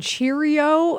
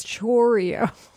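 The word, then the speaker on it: Chourio: I love that it's Kitty and people are, it's not you, but people keep calling him Jackson Cheerio. And I'm like, it's Chorio. – It is? – Well, it's C-H-O-U.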